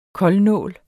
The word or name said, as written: koldnål